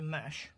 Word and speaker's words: Mesch: Claudia Mesch, that's how you say her name, M E S C H, Art and Politics, another survey book, right?